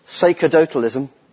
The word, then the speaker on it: sacerdotalism: I think it's pronounced sacerdotalism. Sacerdotalism. Sacerdotalism. There you go.